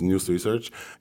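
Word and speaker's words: Nous Research: It's written N-O-U-S. So I mispronounced it now multiple times, but like it's Noose Research.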